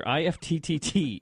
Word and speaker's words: IFTTT: IFTTT, if this, then that. Explain to our listeners what that means. – Yeah, so IFTTT, or to make it easy, we can just pronounce it as IFT.